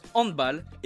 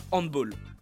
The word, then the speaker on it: handball: La prononciation à l'anglaise est donc fausse. Et eh bien voilà, vous savez maintenant pourquoi ce sport se prononce handball et pas handball.